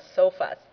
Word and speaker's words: SOFAs: We use the short name of SOFAs. The phonetically sounds like SOFA, like the SOFA there.